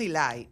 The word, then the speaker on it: Elay: ¿Cómo escribimos Eli? Porque Eli es así se pronuncia. – E-L-A-Y. E-L-A-Y. – Eli. – Ojo y oído. E-L-A-Y.